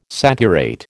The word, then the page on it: saturate: American English